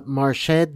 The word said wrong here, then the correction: Marquis: de Gramo? – No. – De Gramo? – I say Marquis, right? – The Marquis de Gramo.